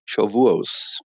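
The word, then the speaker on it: Schawuot: Ja, also es gibt verschiedene Aussprachen im Hebräisch und in die europäische aschkenasische Aussprache, mit dem ich aufgewachsen bin. Man sagt statt Shavuot, Shavuos.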